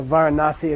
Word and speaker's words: Varanasi: Okay, so Varanasi, if you want, or Benares. Varanasi nowadays they call it in the West.